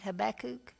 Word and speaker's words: Habakkuk: I think I'm saying that right, Bob. Am I saying that right? Habakkuk? Okay. Habakkuk really had difficulties and reasons to complain.